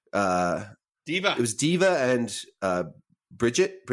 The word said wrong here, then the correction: Brigitte: Bridget. Bridget. – Yeah, Bridget. Yeah, Brigitte. Brigitte. Brigitte. I never can say her name right, and I then I then I remember, and then I fuck it up the next time.